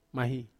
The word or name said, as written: mahi